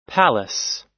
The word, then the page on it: palace: American English